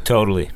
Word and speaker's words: totally: And the other thing is, even though it is spelled T-O-T-A-L-L-Y, it's more of a D sound. – Totally.